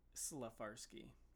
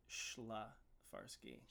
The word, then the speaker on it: Szlafarski: Okay, so I say Szlafarski. Which I hate saying because I have a really sibilant voice. But Szlafarski. – Szlafarski. – Would be phonetically correct.